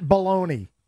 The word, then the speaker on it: Belloni: His name's Matt Baloney. – B-E-L-L-O-N-I. – Okay. Right. That sounds like how you'd pronounce it, right? – Sure.